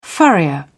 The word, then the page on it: furrier: British English